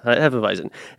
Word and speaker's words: Hefeweizen: And stay tuned for the return of Bananas Foster Hefeweizen. I think I said that right. Hefeweizen? Yeah, Hefeweizen.